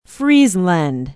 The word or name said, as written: friesland